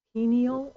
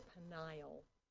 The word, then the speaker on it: Peniel: And in the morning, Jacob names the place Peniel or Peniel. It's spelled P-E-N-I-E-L or P-E-N-U-E-L.